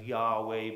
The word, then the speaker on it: Yahweh: And God gives him the name Lord, all capitals, Lord, L-O-R-D, and then they pronounce that as Yahweh, but Y-H-W-H.